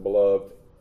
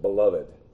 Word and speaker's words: beloved: Those God has accepted, and some people pronounce it beloved, some people pronounce it beloved. But that is because the beloved is Christ.